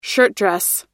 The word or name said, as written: shirtdress